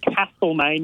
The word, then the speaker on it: Castlemaine: This is at Camp Reserve, love the name, in Castlemaine, and make sure you pronounce it Castlemaine, not Castlemaine.